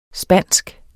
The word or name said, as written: spansk